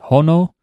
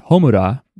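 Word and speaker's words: hono (炎): It goes from the single word for flame is he, and then on top of each other, that kanji is pronounced hono, or sometimes it's pronounced homura, which is the name of the theme song from the Demon Slayer movie.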